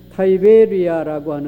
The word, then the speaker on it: 타이베리아: 디베리아라고 하는 곳은 영어에서 하는 발음은 타이베리아라고 하는데, 지금도 갈릴리, 이스라엘의 갈릴리에 가면은 타이베리아라고 하는 이 갈릴리 바다의 서쪽에 있는 한 도시가 있습니다.